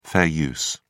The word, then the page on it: fair use: British English